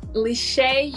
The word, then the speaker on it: Le'Shea: Le'Shea, you were very close.